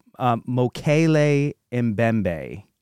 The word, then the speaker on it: Mokele-Mbembe: Dr. Roy Mackle, he says it's pronounced Mokele-Mbembe.